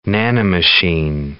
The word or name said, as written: nanomachine